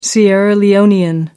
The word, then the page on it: Sierra Leonean: American English